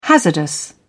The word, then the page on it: hazardous: British English